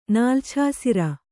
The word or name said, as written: ನಾಲ್ಛಾಸಿರ